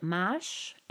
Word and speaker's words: mâche: This is in French, they called it mâche, M-A-C-H-E. I guess the E is silent.